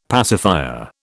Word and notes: pacifier: British English

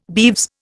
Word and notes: beeves: Indian

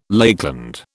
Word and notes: Lakeland: British English

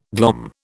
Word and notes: glom: British English

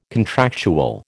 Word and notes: contractual: American English

